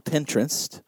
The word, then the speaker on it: Pinterest: I was on Pinterest.